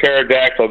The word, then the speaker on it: pterodactyl: Why can't you hear a pterodactyl go into the bathroom? – Why can't you hear a pterodactyl go to the bathroom? What is the answer? – I just said, because the P is silent.